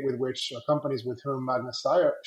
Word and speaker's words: Magna Steyr: So, for example, thinking of Magna Steyr, like, would it make sense to try to, by the way, am I finally pronouncing that correctly? – Yes, perfect.